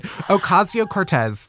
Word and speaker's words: Ocasio-Cortez: Ocasio-Cortez. That's how you say her name, right? – Yeah.